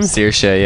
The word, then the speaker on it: Saoirse: If I was a girl, my name would be Saoirse. – Saoirse? – Is that an Irish name? – It's an Irish name. It's spelled not how it sounds.